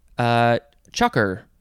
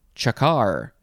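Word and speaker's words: chukar: Next word is chucker or chakar. It is spelled C-H-U-K-A-R. I'll say chakar. That seems more appropriate.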